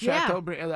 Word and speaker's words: Chateaubriand: Is that how you say it? Chateaubriand. – Yeah.